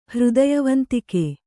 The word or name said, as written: ಹೃದಯವಂತಿಕೆ